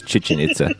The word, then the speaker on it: Chichen Itza: How do you say that one? I think it's Chichen Itza, right? – Yes, we'll go with that.